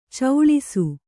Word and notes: ಚೌಳಿಸು: kannada; cauḷisu

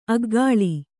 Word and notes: ಆಕಳಿ: ākaḷi

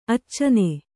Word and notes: ಅಚ್ಚನ: accana

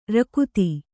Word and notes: ರಕುತಿ: rakuti

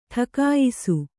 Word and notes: ಟಿಕಾಯಿಸು: ṭikāyisu

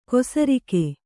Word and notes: ಕೊಸರಿಕೆ: kosarike

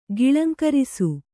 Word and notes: ಗಿಳಂಕರಿಸು: kannada

